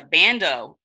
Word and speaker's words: bandeau: bandeau, like a band, how do you pronounce it? Bandeau. Mm-hmm.